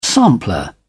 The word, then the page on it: sampler: British English